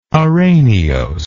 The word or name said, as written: araneose